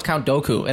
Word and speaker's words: Dooku: Oh, it's Count Dooku,